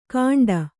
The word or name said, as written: ಕಾಂಡ